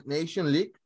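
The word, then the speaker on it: Nations League: fue en la final de la Nation League. ¿Lo pronuncié bien o lo dije mal, estimado? No, lo dijiste perfectamente.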